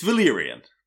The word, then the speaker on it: Valerian: Yes, after that musical sting, let's join Valerian, I'm not sure how you say his name. It's Valerian.